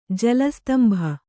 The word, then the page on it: ಜಲಸ್ತಂಭ: jala stambha